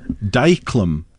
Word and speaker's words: dyclum: Dyclum. The cuttlefish. This name is given humorously to the cuttlefish because its suckers adhere like dyclum, which is the Manx pronunciation of diachylon, sticking plaster.